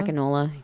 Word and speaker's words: Akinola: I think it's Sagan Akinola. – Akinola, yeah.